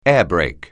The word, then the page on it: airbrake: English